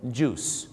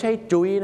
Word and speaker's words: juice: juice ไม่ได้ออกเสียงว่าจุ้ยนะฮะ juice นะครับผมท้องเสีย juice เลยนะครับผมน้ำผลไม้ไม่ใช่จุ้ย